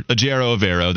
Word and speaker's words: Ejiro Evero: And he says, hi, it's Edro Evero. Yeah. And if you look it up on Google, it gives you pronunciation guides. So just want to be clear, Edro Evero, that's correct from the man himself.